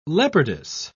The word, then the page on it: leopardess: British English